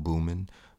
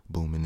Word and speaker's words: Boomin: If you don't know Boomin, Boomin is another nickname. But this one was given to me by a college friend. And, you know, Boomin rhymes with Newman.